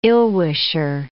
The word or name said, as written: ill-wisher